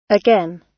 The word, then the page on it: again: British English